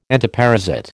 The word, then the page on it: entoparasite: American English